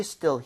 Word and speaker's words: still: 자, 그리고 전에도 말씀드렸지만 얘는 그 훔치다 할 때 S-T-E-A-L과 스펠링도 틀리지만 발음 자체가. 그렇죠. So steal 이렇게 E 이러면 안 돼요. 얘는 입모양 벌리지 않은 상태로 편하게 갈게요. 두 번 갑니다.